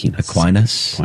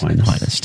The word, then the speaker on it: Aquinas: If I say his name right, Aquinas. – Aquinas. Thomas Aquinas. – Is that right? – Yeah, okay. I believe so.